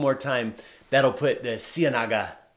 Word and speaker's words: Ciénaga: And from Monteria, we, if you'll advance it one more time, we went out to a small town by car uh, called Ciénaga. Did I say that correctly, Anderson? Ciénaga. Close. Tell me. Let's let's let's have it the Colombian way. – Ciénaga. – Ciénaga. – You got it.